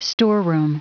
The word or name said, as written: storeroom